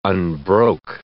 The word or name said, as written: unbroke